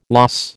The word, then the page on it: loss: American English